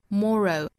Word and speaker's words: moral: set us free 后面的 until 跟后面 skills 包括前面的 essential，注意尾部是舌侧音啊，包括最后一句的 moral，那一定要注意尾部的舌侧音。发音的时候呢舌尖是底上齿音，然后我们用喉咙去发声的发音，一定要注意舌尖抵住上齿音，不要落下来，不要弹下来啊，应该是抵住之后再发声的。